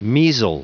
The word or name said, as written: measle